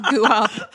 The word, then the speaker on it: Goop: I like in my head, I pronounce it like co-op. – Goop. Goop.